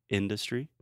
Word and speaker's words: industry: So industry, the stress is first on the first syllable, industry. And the second syllable is the schwa, in the, du uh. But for some reason, Trump puts the stress on the schwa, which never happens in English.